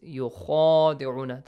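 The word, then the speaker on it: يخادعون: So, the first important point on Tajweed rules is yukhadi'una, the letter kha. It has an alif, yes, alif mad. It's pronounced the same as the name of this letter, kha. Alif, ba, ta, tha, jim, ha, kha. Okay, heavy letter. There is an alif, you gotta stretch it for two counts.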